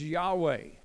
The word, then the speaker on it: Yahweh: The name of God in Hebrew is without pronunciation, YHWH. And so we have to put vowels into it. His name is Yahweh.